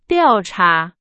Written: 调查

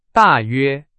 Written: 大约

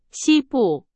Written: 西部

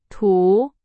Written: 图

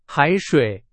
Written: 海水

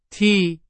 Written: 替

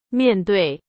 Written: 面对

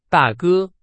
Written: 大哥

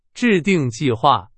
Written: 制订

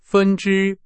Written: 分之

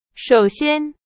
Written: 首先

